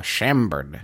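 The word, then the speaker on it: Chambord: Chambered. Am I pronouncing that correctly? That's the raspberry liqueur. Chambered.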